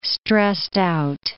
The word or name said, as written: stressed-out